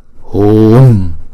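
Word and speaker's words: hūṃ: Làm chúng ta kéo dài chữ U, đọc hu trước rồi mới đóng chữ U đó lại. Hùng. Không. Chứ chúng ta không đọc là Hùng. Không. Đọc là Hùng, Hùng.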